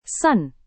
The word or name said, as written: sun